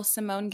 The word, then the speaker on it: Symone: It's just my name, Rachel Simone Gilliam. Simone is spelled S-Y-M-O-N-E.